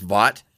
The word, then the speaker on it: Vought: And representing Amazon Prime, the group that heroically separated themselves from Voight Media and now are thriving. The Boys. – Right, isn't it pronounced Vought? – I know what I said.